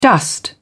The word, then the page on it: dust: British English